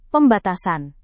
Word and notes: pembatasan: Indonesian

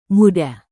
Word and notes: muda: Indonesian

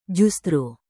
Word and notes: justru: Indonesian

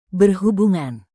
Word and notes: berhubungan: Indonesian

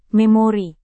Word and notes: memori: Indonesian

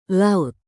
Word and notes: laut: indonesian

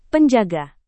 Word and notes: penjaga: Indonesian

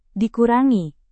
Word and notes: dikurangi: Indonesian

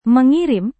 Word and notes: mengirim: Indonesian

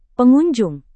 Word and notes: pengunjung: indonesian

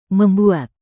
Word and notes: membuat: indonesian

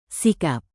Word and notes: sikap: Indonesian

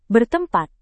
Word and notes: bertempat: indonesian

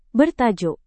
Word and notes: bertajuk: Indonesian

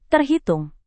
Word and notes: terhitung: indonesian

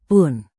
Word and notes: pun: indonesian

